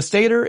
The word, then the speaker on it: stator: You have the stator, or stator if you prefer, but stator is typically how I heard it pronounced.